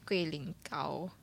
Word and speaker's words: guilinggao: Okay, then the next one I tried was the herbal jelly, the guilinggao. Is that how you pronounce it? – Yep.